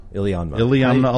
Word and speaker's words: Iliamna: The little town is called Iliamna. – Iliamna, Alaska.